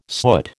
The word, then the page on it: soot: American English